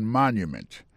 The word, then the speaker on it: monument: Pour mot monument, il tombe sur le premier syllabe là. The Washington Monument. Monument, c'est comme ça on dit monument en anglais.